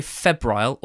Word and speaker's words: febrile: that described the atmosphere as F-E-B-R-I-L-E. Now, would you say febrile or febrile? – Febrile, I think.